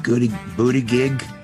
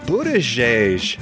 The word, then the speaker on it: Buttigieg: Pete Goody Booty Gig. – Did I say it right? – Buttigieg.